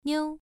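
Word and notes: niu: tone 1

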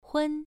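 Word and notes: hun: tone 1

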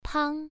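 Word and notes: pang: tone 1

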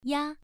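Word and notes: ya: tone 1

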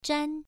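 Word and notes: zhan: tone 1

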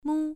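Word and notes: mu: tone 1

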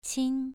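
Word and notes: qin: tone 2

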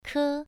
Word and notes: ke: 1

